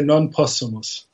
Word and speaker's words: Non Possumus: And it was sparked by an essay that appeared in the magazine First Things called Non Possumus. Is that how you would pronounce that? – Yeah, well, I would say Non Possumus. – Okay. I took some Latin in high school, but it's been a while. – No, they're different systems of Latin pronunciation.